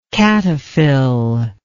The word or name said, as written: cataphyll